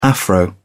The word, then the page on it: afro: British English